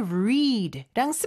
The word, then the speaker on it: read: 에서 read, R-E-A-D. 이게 read랑 스펠링은 똑같지만 과거 형태 read입니다. 그래서 그렇게 읽은 거고요.